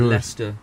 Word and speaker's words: Leicester: They pronounce it Leicester.